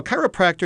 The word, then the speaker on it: chiropractor: It's spelled differently, C-H-I-R-O. But it comes from the Greek word meaning hand. And so a chiropractor is somebody who practices a chiro, whatever that is. So although it rhymes with velociraptor, actually chiropractor has nothing in common with velociraptors, which I never hoped to meet.